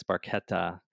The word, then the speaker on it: Barchetta: It's, I always see people say Barchetta, but it's Barchetta.